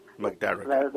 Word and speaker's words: Magdaragat: So I think the pronunciation is Magdaragat. – Magdaragat. – The stress on the second syllable.